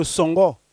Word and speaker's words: Songo: On l'appelle le Songo. Ça, c'est avec l'accent de chez nous. – Oui. – Songo, ça s'écrit S-O-N-G-O.